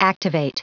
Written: activate

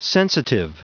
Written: sensitive

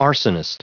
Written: arsonist

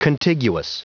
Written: contiguous

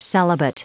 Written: celibate

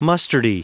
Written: mustardy

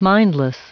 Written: mindless

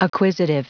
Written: acquisitive

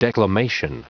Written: declamation

